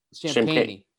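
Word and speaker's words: Champagnie: Am I saying that right? Is that how you say it? – Champagne. – Champagne.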